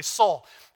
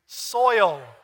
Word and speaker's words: soil: Okay, you're going to have to try hard if you grew up in the South because your natural is just going to be soul. But we're going to say it correctly. Ready? One, two, three. Soil.